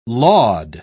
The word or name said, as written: laud